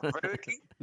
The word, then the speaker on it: Wedekin: Tal vez en alemán la W se pronuncia V, o sea, sería Wedekin, pero lo dijiste bien, como yo lo digo, para no complicarme también, Wedekin.